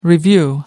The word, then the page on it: review: American English